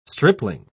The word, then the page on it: stripling: British English